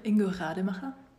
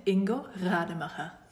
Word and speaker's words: Ingo Rademacher: How do you say his name correctly? – Ingo Rademacher. – Say it one more time. – Ingo Rademacher. – Ingo Rademacher. – It's very good.